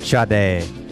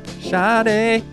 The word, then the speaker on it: Sade: See, I still mispronounce it even though I know how to pronounce it. – Sade. – Sade.